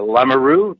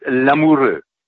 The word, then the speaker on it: Lamoureux: And that's a really nice pronunciation of my name, uh, Lamoureux, English, and in French it's Lamoureux.